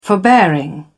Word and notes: forbearing: British English

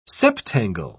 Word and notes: septangle: British English

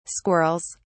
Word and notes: squirrels: British English

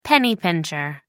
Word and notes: penny-pincher: American English

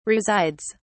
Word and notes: resides: British English

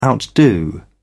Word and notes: outdo: British English

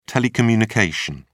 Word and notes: telecommunication: British English

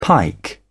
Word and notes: pike: British English

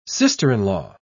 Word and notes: sister-in-law: British English

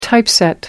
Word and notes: typeset: American English

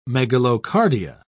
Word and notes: megalocardia: British English

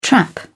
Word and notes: trap: British English

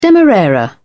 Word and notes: demerara sugar: British English

